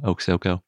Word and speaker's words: Oksoko: This one's named Oksoko, and thank you to the authors for giving me a pronunciation guide. I really appreciate that. It's actually a pretty easy dinosaur name too, Oksoko.